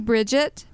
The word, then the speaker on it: Bridget: Okay, my name is Bridget, B-R-I-D-G-E-T, last name Lyman, and I'm with the Caddo Council on Aging.